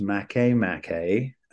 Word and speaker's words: Makemake: It is pronounced Makemake.